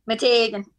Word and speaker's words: Meteghan: I just want to say like Meteghan, like the accent. That's actually a word that I say often. Meteghan, because when anyone brings it up, I say it like that. Meteghan, because that's how I've heard it pronounced.